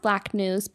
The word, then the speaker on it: BLKNWS: So six Black News, which is pronounced Black News, but spelled in all caps, BLKNWS with the registered trademark, are currently installed in various public sites around the city with more to come.